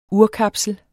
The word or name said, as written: urkapsel